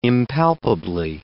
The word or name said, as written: impalpably